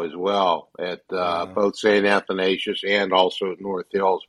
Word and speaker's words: Athanasius: And by the way, that's how you say it, Arv. Athanasius.